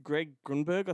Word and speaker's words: Greg Grunberg: And Greg Grunberg, I think that's how you pronounce his name. – Yeah.